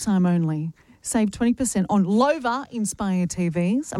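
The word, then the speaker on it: Loewe: You can upgrade your home entertainment with the premium Lowy TVs at Harvey Norman. – Lover. – Oh, is it Lover? – See, at the top how it says Lover. – Oh, yes. – Almost rhymes with sofa.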